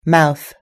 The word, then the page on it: mouth: British English